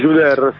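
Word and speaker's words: Djouder: Assolutamente, questo lo ripetiamo per gli ascoltatori, era Disintegrati di Ahmed Juder, se non sbaglio. – Sì, Juder, sì, tu la pronunci molto bene, molto meglio di me il francese.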